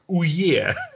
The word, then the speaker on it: Ouya: Это «Уя». Читается как... Точнее, читается правильно как «Уе».